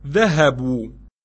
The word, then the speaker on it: ذهبوا: ذهبوا. – They went. The subject is the واو. The aleph after the واو is not pronounced.